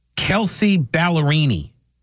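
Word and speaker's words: Kelsea Ballerini: Kelsey Ballerini.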